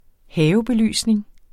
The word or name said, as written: havebelysning